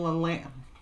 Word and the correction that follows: Lyanna: I can't say her name. – Lyanna. – Lyanna Mormont.